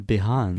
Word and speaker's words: Behance: Behance. – Behance. Behance. – Kind of like, yeah, Beyonce.